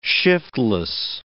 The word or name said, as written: shiftless